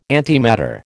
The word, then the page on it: antimatter: American English